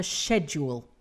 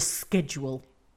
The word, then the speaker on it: schedule: It is one of my bête noire that everybody mispronounces schedule nowadays. Mm-hmm. I guess it is a sad indication of the creeping Americanization of the English language. Jim's pronunciation was absolutely spot on. I cannot tell you how long it is since I've heard the word so properly rendered.